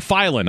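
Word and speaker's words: Philon: His name is Darius Philan. Or Filan, I'm sorry, Filan. I've said that wrong.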